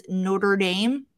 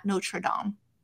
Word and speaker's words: Notre Dame: We're saying it very, you say it very Midwest. It's Notre Dame, not Notre Dame.